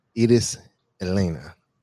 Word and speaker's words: Iris Helena: So for everybody who doesn't know, this is Iris Helena. Am I saying it right? – It's Iris Helena. That's how you say it. You can say just Iris. It's fine. – Iris Helena.